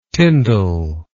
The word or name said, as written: tyndale